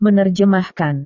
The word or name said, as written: menerjemahkan